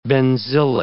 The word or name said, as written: benzylic